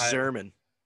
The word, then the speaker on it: Yzerman: I think it's Zerman. I think the Y is silent.